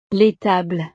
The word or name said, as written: l'étable